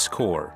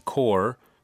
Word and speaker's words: corps: 今天我们要学的词是 press corps. Press is spelled P-R-E-S-S, press, and corps, C-O-R-P-S, corps, press corps.